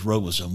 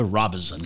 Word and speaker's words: Robeson: I was at a gathering the other day, and some Native American says, you know, all those whites call us Robeson. We're Robeson. – How about that? – But it is interesting the different pronunciation. It's R-O-B-E-S-O-N.